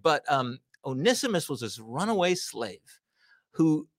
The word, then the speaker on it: Onesimus: Because this, so Onesimus or one Simus, that's how you spell it. Onesimus, it looks like one Simus, but and there's these names like Philemon, right?